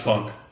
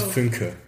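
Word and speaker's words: Fünke: No, that's how it's spelled, but it's pronounced Funk. – Oh, is it? – Yeah, it's pronounced Finke.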